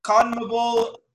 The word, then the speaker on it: CONMEBOL: The CONMEBOL, oh my gosh, I might have butchered that. – Not bad. – CONMEBOL World Cup qualifiers. There we go.